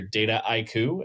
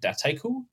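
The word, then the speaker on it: Dataiku: But the word itself is a portmanteau, so a mashup of data and haiku, right? And haiku is a Japanese poem, for those aren't familiar with it. So that's the biggest is in the US, you tend to hear DataIQ as sort of four different syllables. And the French tend to say it DataIQ, since that's okay. – How they pronounce data.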